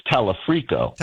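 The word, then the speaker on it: Talarico: If you and I sit here on November the 4th and 5th and say, okay, we got Dr. Abdul, we have Tallarico, we have Angie Nixon, maybe do you and I not perceive reality? Is that possible? – I think you're mispronouncing his name, Bill. It's Talafrico.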